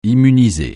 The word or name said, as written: immuniser